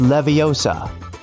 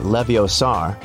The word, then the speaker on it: Leviosa: Hey Siri, it's Leviosa, not Leviosar.